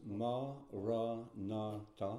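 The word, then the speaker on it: maranatha: We say the word in four syllables, ma, ra, na, ta.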